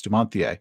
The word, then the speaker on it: Dumontier: And my favorite ones were made by a French gunsmith named Dumontier, is how it's spelled, but it's Dumontier.